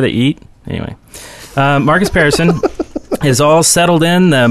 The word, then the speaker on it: Pearson: And in the news today, Marcus Pearson, and that is how you say his name, by the way. It's Pearson, not person, not parson, not whatever.